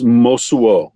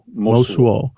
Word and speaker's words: Mosuo: I want to switch gears a little bit here to talk about the Mosu people. Is that how you pronounce? – Well, I think it's Mosuo. – Mosuo.